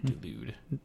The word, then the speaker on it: Delude: Nick Delude.